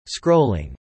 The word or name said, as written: scrolling